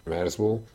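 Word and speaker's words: Merzbow: Merzbo 1979 óta több száz felvételt jelentetett meg. A projekt neve Kultschwitzer Merzbo című alkotásából ered, és a kiejtése is megegyezik azzal.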